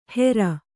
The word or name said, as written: ಹೆರ್